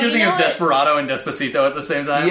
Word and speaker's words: Despacito: Expe Despaciado. – Oh, Despacito? – Despacito. – You say that wrong every time.